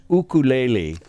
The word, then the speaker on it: ukulele: By the way, it's pronounced ukulele. – Ukulele. – Yeah. – Yeah. – Not ukulele.